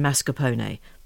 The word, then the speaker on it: mascarpone: And I said limoncello, and Anna, who's Italian, says, Jane, it's limoncello, and please pronounce all the consonants in mascarpone.